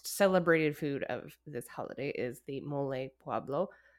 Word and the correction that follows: poblano: I don't know if I'm saying that right. Poblano.